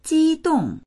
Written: ji1dong4

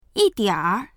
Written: yi4dianr3

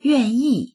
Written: yuan4yi4

